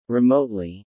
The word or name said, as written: remotely